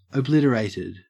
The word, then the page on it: obliterated: Australian English